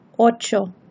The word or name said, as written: ocho